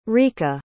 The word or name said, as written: Rica